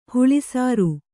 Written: ಹಾಲುಸೋರೆ

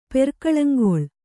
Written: ಪೆರ್ಕಳಂಗೊಳ್

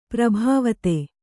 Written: ಪ್ರಭಾವಿತೆ

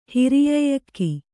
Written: ಹಿರಿಯಏಲಕ್ಕಿ

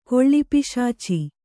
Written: ಕೊಳ್ಳಿಪಿಶಾಚಿ

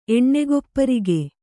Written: ಎಣ್ಣೆಗೊಪ್ಪರಿಗೆ

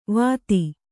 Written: ವತ್ತಾ

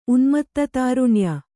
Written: ಉನ್ಮತ್ತತಾರುಣ್ಯ

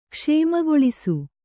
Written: ಕ್ಷೇಮಗೊಳಿಸು

